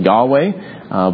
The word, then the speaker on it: Yahweh: That means it's not simply the word for Lord, which would be Adonai, would be my Lord, but it is the word W-H-Y-H in Hebrew, what we usually pronounce as Jehovah. Some people pronounce it as Yahweh, but it is the name that God reveals in the Old Testament.